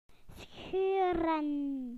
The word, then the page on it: to rip: British English